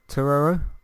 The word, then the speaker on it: Turturro: Tur- I said his name correctly earlier. Now I can't say it properly. John Turturro. Turturro.